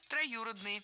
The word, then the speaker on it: троюродный: Троюродный брат. Это неверно. Правильно? Троюродный. Ударение ставим на гласную «ю». Брат троюродный. И сестра, кстати, тоже троюродная.